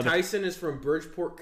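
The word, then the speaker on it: Taisun: I'm assuming you pronounce it Tyson, but it's T-A-I-S-U-N.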